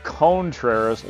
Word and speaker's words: Contreras: That was Wilson, as Pat Hughes says, Contreras, long O for Wilson Contreras, and O official. I don't think that official actually requires the long O, but you know what?